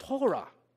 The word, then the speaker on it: Torah: Well, the word law in Hebrew here is pronounced Torah, right?